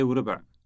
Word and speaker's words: وربع: واحدة وربع. واحدة وربع. – You're literally saying 1 1/4. Note the wa sound for and between the two words.